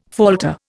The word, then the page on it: falter: Indian